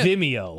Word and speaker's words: Vimeo: Vimeo, is that how it's pronounced? – Yeah. – I've never said that word out loud. Yeah. Vimeo.